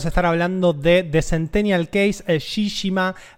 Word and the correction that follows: Shijima: a ah, Shijima, que mi sensei no me escuche pronunciando mal esas palabras.